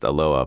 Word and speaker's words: Aloha: A few months after the wedding, an old friend moved to Aloha, pronounced Aloha by the locals, Oregon.